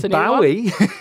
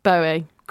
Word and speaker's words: Bowie: David Bowie. – I've always said Bowie. Do I say it wrong? – No, you are saying it right.